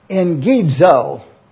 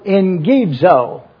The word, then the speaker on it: eggizo: If you'll notice carefully what the word is, again, it is E-G-G-I-Z-O, Engidzo. I even like to try to pronounce that for you, Engidzo. The reason is because when you find two G's in the Greek, it is pronounced N-G. So you can pronounce this E-N-G-I-Z-O, Engidzo, draw near.